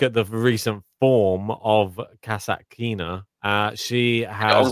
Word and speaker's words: Kasatkina: I finally pronounced her name right for the first time ever. – But, how did you used to say it? – I don't know. It's Kaskaskina or something. I was like Kasatkina.